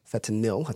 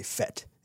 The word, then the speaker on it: fentanyl: Fentanyl, I think is actually how it's pronounced. Fentanyl. – Yep. – I always say fet. It's fentan.